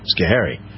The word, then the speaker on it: Schoharie: There's no better place to learn how to pronounce names like Schoharie than Schoharie.